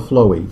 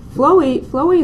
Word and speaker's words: Phloe: Spelled P-H-L-O-E. – Yeah. – Kind of a weird Some people pronounce it flow. That may be more correct, but I'm not sure. But we call it Flowey because that's what we started calling it, and that's what we call it now.